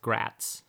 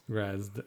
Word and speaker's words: Graz'zt: we keep pronouncing his name as kind of Gratz, and just to dispel any confusion maybe that people are hearing, it's the demon prince Grazd, the G-R-A-Z-Z-T, but it's pronounced Gratz.